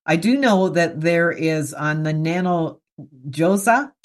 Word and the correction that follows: Nano Ojas: Did I say it right? Nano Josa? – Nano Ojas. – Nano Ojas. Okay. Nano Ojas.